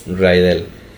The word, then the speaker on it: Rydell: Posteriormente mencionan que un programa de televisión llamado National Bandstand va a emitir mm, un baile en el gimnasio del Instituto Rydell ¿Ya lo dije bien? – Yes. Raidel.